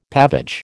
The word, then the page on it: pavage: American English